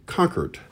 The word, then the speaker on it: Concord: And in Boston, it's said Concord is how you pronounce it.